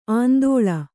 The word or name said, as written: ಆಂದೋಳ